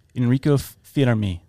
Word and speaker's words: Enrico Fermi: One of them is Enrico Fermi, I think you say. That's how you say his name. Enrico Fermi,